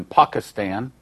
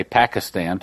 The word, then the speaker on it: Pakistan: As a result of that, he got saved, radically saved, and him and his wife have started some 300 churches in Pakistan. I used to say Pakistan till I learned from him how to really pronounce it, Pakistan.